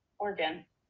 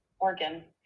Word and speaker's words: Oregon: Oregon. – Oregon? – Oregon. It is not three syllables. There's only two.